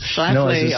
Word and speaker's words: Schlafly: Her name is Phyllis Schlafly. – Yes, Schlafly. – I was saying it wrong before, but I have it now.